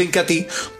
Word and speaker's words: Incatí: Medio litro de Incatí, se escribe Incatea, pero se pronuncia Incatí, por solo 39 mil pesos.